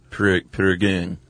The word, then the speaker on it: Prägung: Like an A with a new amount, sort of a U. Prägung.